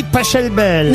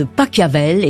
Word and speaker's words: Pachelbel: Que vous ayez dit Pachelbel.